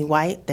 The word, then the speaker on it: White: I'm Lieutenant Courtney White, that's spelled C-O-U-R-T-N-E-Y, last name W-H-I-T-E, and I am the Chief of Staff for the Arlington Police Department.